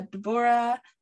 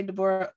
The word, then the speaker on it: Deborah: So usually a lot of people say Deborah because that's the like common way to pronounce it. Yeah, but that's why Deborah, I assume. – Yeah. So don't even worry about it. But yeah, Deborah. And I say Deborah Odie.